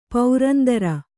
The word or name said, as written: ಪುರಂದರ